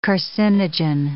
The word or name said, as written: carcinogen